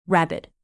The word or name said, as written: rabid